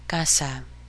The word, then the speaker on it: casa: An A is always A. So, casa.